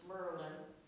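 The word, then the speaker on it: Maryland: In the state, I learned to pronounce Merlin, which is just south of the Mason-Dixon line, and I didn't think it was that south, but it turned out it was.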